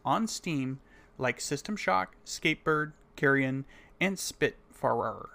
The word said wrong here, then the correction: Spiritfarer: Or, no, Spiritfarer.